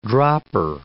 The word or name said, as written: dropper